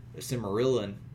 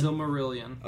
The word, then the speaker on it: Silmarillion: If books, have you read Tolkien's other stuff like the Silmarillion? I always said Aurelian. Is it Rillian? – Silmarillion. – Okay, yeah, I said it right.